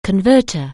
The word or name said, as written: converter